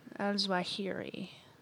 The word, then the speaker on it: Zawahiri: Oh, Al-Zawahiri? Is that how we pronounce his name? – Yeah. – Al-Zawahiri.